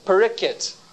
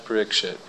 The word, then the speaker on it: Parikshit: Similarly, they say Parikit. Maharaj Parikit. So you can also use these Bengali pronunciations if you want, but then we would suggest that you be consistent. We all say Daksha, we all say Diksha, we all say Shiksha, and we all say Prikkit. I know the reason why. So, I suggest you be bold. Go right ahead and say Maharaj Parikshit.